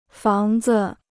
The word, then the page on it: 房子: fáng zi